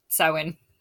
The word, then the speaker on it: Samhain: I've heard Samhain. – Is what it looks like when it's spelled, yeah. But it's like an Irish word and it's pronounced Samhain. – Samhain, okay.